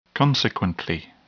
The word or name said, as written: consequently